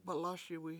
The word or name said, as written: balASiwi